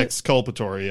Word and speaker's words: exculpatory: Exculpatory? Is that how you pronounce it? – Exculpatory, yeah.